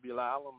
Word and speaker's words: Bilal: Yes, I'm Wally Ayu Bilal. I'm the imam out of the Philadelphia area. My name is spelt W-A-L-I, middle name A-Y-Y-U-B, and last name B as in brother, I-L-A-L.